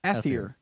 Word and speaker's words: Atheer: Atheer. – You say Atheer. Atheer. – Atheer. – Like your, yeah. – So like ethical. – No, like an apple.